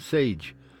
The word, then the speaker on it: Sage: And then one from Sage, rhymes with beige.